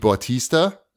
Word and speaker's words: Bautista: And it stars Dave Bautista.